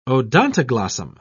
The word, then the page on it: odontoglossum: British English